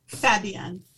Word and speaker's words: Fabienne: Say it for me again. – That's okay. Fabienne. – Fabienne. – Yes, like fabulous.